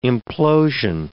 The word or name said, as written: implosion